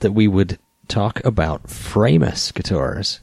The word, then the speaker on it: Framus: I've heard it said Framus. – Framus. Framus. Nat speaks German. So a little bit. I asked him what would the correct pronunciation be, and he... – I said Framus.